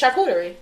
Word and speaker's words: charcuterie: Not, am I saying that right? It's charcuterie. Somebody type it. it's called charcuterie.